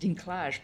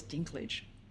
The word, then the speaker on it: Dinklage: And I think, actually, casting Dinklage, I didn't realize it was pronounced Dinklage, by the way. I thought it was Dinklage.